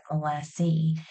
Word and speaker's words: Molassey: Older generations will call it Molassey. So they'll drop the ESS and add an EY, which is really interesting.